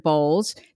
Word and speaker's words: Bolles: As a career coach and counselor of 30 plus years, Shel was trained by Richard Bowles. Did I pronounce that right, Shel? – Yes, you did.